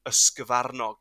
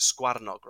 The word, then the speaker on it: ysgyfarnog: So a hare in Welsh is an ysgyfarnog, or as some people pronounce it, sgwarnog, right?